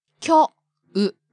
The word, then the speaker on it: 今日: And speaking of long vowels, 今日 has long o sound too. – So 今日 means today. – Right. It's spelled 今日, but pronounced 今日.